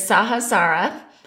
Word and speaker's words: Sahasrara: The Sanskrit term is Sahasrara, and it is spelled S-A-H-A-S-R-A-R-A.